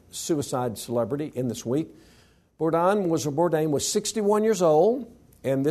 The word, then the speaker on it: Bourdain: This guy named Anthony Bourdain, is that the right way to say it? Bourdain? Thank you.